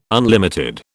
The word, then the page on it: unlimited: British English